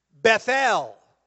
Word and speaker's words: Bethel: Now, when we say Bethel, really, and that's why I said it when I was reading it, it's an unusual way to pronounce it. Bethel. Because that's actually how the Hebrews would pronounce it. Because it's a compound word. Beth means house. El means the Lord or Jehovah.